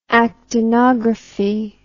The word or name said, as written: actinography